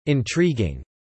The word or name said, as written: Intriguing